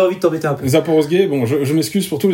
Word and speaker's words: Zaporozhye: Zaporogi. qu'est-ce que... Comment, Zaporogi, c'est pas ça Zaporozhye. Zaporozhye Voilà.